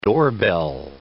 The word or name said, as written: doorbell